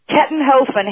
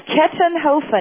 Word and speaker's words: Kettenhofen: Kettenhofen, Harry. Don't worry, I won't quiz you on the pronunciation. – Say it again, Kettenhofen? – Yes, Kettenhofen. It's K-E-T-T-E-N. – Oh, my gosh, it's just like it looks, huh? – Yep, H-O-F as in Frank, E-N.